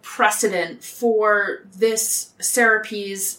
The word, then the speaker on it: Serapis: I believe it's actually pronounced Serapis, S-E-R-A-P-I-S, to basically garner support from Greeks and Egyptians.